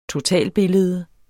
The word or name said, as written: totalbillede